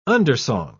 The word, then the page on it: undersong: British English